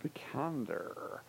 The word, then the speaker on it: Vikander: Vikander. – And I think he pronounced it perfectly. – Did I? With a little more phlegm.